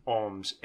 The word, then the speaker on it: Alms: Alms. A-L-M-S.